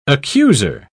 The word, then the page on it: accuser: British English